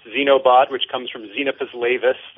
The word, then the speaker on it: Xenobot: We'll take either, but we usually use Xenobot. – Xenobot, okay.